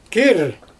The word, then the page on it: kiir: Xyzyl